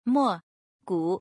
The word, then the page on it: 莫谷: Mo Gu